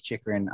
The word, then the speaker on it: Chychrun: Pronounced his own name as Chychrun on Elliot Friedman's podcast.